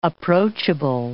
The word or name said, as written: approachable